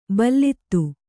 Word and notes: ಭಲ್ಲಾತ: Kannada

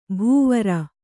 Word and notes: ಭವಾರಿ: bhavāri

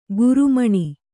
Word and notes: ಕಾರ್ಮಣಿ: Kannada

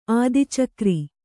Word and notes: ಆದಿಚಕ್ರಿ: ādicakri

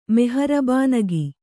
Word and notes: ಮೆಹರ್ಬಾನಗಿ: meharbānagi

